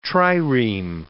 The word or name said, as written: trireme